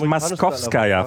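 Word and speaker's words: Maskowskaja (Московская): Das heißt übrigens Maskowskaja, falls ich das mal hier korrigieren darf. – Moskowskaja, ja. – Im Russischen wird kein O ausgesprochen, außer es ist betont.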